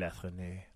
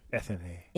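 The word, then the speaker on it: Athénée: Say it like in a French. Hotel Athenae. – Athenae. – Athenae. Hotel Athenae. – Right by the Eiffel Tower. – Start with croissant. – Croissant. – Athenae.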